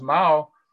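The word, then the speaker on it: Mao (茅): And what's interesting, again, the Chinese characters, the character for thatch, it's pronounced Mao, and the character for all grasses is chao.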